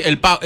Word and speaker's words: PAOK: Acá nombra, los clubes serían, decime si lo pronuncio bien, Olympiacos FC y PAOK. – Sí, el PAOK.